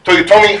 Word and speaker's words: Toyotomi: I don't know where the emphasis is in that, but I know Hideyoshi. Is it the second one? Toyotomi? It's the second to last syllable? Yeah. Toyotomi Hideyoshi.